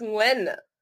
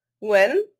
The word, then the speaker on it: Nguyen: It's Nguyen. Nguyen.